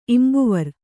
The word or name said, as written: ಇಂಬುವರ್